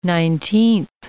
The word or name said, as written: nineteenth